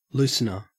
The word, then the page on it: loosener: Australian English